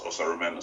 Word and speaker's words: Osaruman: So I introduced myself as Osaruman Osamoyi. – Okay. – Because of how it's spelled, it's the most straightforward way to pronounce a name.